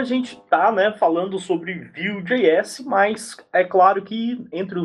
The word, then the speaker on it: Vue.js: Então aprenda aqui conosco que é Vue.js, tá? Que vem de... se pronuncia como se fosse Vue em inglês, MVC, camada Vue, Vue.js.